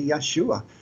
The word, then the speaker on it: Yeshua: his name was Joshua, and the Hebrew language doesn't pronounce the J, so it would be Yeshua.